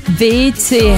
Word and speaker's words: Weeze: Eben auch in Deutschland das Brookville Festival, und zwar am Flughafen WC heisst es. Versuch zum Weiss aussprechen, weil W-E-E-Z-E geschrieben, aber tatsächlich heisst WC.